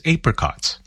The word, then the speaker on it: apricots: That was Maya Cumming, a Sydney, Australia native who records as Maya, spelled M-A-Y-A. Now, I would pronounce the song name Apricots, but I guess the Aussie pronunciation is Apricots.